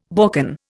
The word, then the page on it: Balkan: Indian